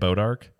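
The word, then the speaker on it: Bois d'Arc: Well, it's like that town in Missouri that's called Boy Dark. Boy Dark. – Well, it's pronounced B-O-I-S. – Don't you say it Bodark? – Or Bodark.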